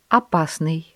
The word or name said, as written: опасный